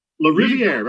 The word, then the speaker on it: Lariviere: Lariviere, very close.